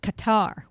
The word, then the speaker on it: Qatar: has now begun shifting its operations to the Gulf state of Qatar. Well, that's Q-A-T-A-R, pronounced, I think, more correctly, Qatar.